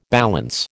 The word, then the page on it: balance: American English